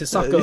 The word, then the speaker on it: Hisako: Like Mike says, they're all from Agnes to the Exorcist girl, Hisaki. I can't say her name. I'm sorry. – It's in her theme. They say Hisako. It's Hisako.